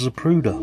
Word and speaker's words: Zapruder: She said she didn't know how it was spelt, but it's pronounced Zapruder.